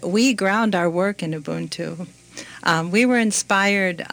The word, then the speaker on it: Ubuntu: In an earlier conversation, we talked about, and you're going to have to help me with the pronunciation with this one, Ubuntu. – Very good. – Ubuntu.